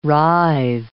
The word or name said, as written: writhe